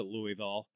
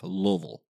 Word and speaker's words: Louisville: so I pronounce it Louisville. But I know it's supposed to be pronounced Louisville or something like that. – Louisville. – Anyway. – You just round it off Louisville. – Okay. – I'll work on that. Like V-U-H-L.